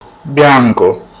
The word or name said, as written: bianco